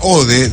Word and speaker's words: Ohde: En esta séptima intervención, el espacio recibirá la obra de la artista visual y diseñadora industrial Clara Ode, o Ogde, se escribe O-H-D-E, Clara Ode debe ser la pronunciación.